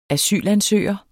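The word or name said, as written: ASYLANSØGER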